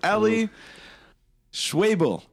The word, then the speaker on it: Schwebel: All right, we are here with Eli Schwebel. – That's right. – Did baby. – I say it correctly? – You did.